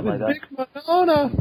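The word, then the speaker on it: Vic Mignogna: Yeah, Vic Minona.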